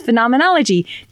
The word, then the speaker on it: phenomenology: She was like, "It's phenomenology, do do do do do do do phenomenology." – Like the Muppets. – Yes. And so now every time I think about phenomenology, I go do do do do do do do do.